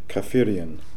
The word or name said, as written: Kafirian